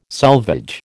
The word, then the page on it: selvage: American English